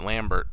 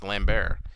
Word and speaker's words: Lambert: Christopher Lambert as Raiden, or a lot of people who pronounce it Lambert, but it is wrong because he is French, and in France it is pronounced Lambert.